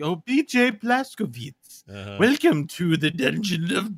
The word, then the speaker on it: Blazkowicz: Blazkowicz. – Oh, it's Witz. – Yeah, yeah, that is how they say it. – Like, I think, like, if you just say it's Witch, but it's Witz.